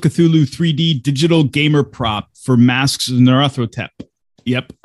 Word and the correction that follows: Nyarlathotep: It says on the cover of second edition, it phonetically spells it out. Why do people keep on getting it wrong? Nyarlathotep. – There we go. Bud said it and pronounced it properly for us.